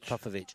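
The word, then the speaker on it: Popović: And I said Popovic? How do you say it? Popovic.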